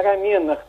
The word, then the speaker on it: рамена: Правильное ударение – рамена.